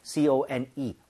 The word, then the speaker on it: cone: 근데 우리가 말하는 그콘 모양을 얘기를 할 때는 CONE, R 사운드가 아예 없이 깔끔하게 네.